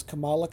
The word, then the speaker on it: Kamala: Yeah, now Miss Marvel is Kamala Khan. And yes, the comic book is pronounced Kamala. The vice presidential candidate is Kamala. So we're learning to pronounce the same name different ways.